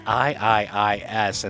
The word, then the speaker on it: IIIS: Now, up next, we have a band that I thought was called Is, that's I I I S, and that also features Dromedarios Mágicos and the tune is called Futuro Incierto. Now, I must point out the band, IIIS, is pronounced IIIs and is an experimental group out of Mexico.